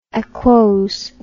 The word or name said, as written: aquosity